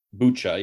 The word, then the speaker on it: Bucha: It's Bucha. – Bucha.